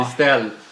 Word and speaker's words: Kristel: Det är inte som kristall, utan det är, alltså, det är Kristel. – Ja, inte Kristel. Som Krister. – Ja, fast med L.